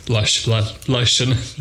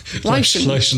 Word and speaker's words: Leyshon: Leyshon. Leyshon is the correct pronunciation.